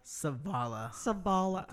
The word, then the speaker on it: Savala: Savala. – The V. Daniel Savala.